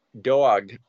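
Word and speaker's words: Dawg: Dog, and that's how it's said, because it's D-A-W-G. So to me, that's Dog. Like if you're from New York, like people from Brooklyn, like Dog.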